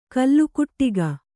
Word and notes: ಕಲ್ಲುಕುಟ್ಟಿಗ: kallukuṭṭiga

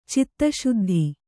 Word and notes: ಚಿತ್ತಶುದ್ಧಿ: citta śuddhi

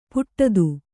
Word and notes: ಪಠಿತ: paṭhita

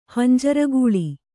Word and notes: ಹಂಜರಗೂಳಿ: kannada

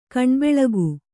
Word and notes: ಕಣ್ಬೆಳಗು: kaṇbeḷagu

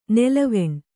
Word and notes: ನೆಲವೆಣ್ಣು: nelaveṇṇu

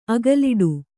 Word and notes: ಅಗಲಾಡು: agalāḍu

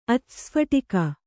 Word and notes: ಅಚ್ಛಸ್ಫಟಿಕ: Kannada

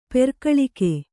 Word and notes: ಪೆರ್ಕಳಿಕೆ: kannada; perkaḷike

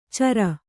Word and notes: ಚರ: cara

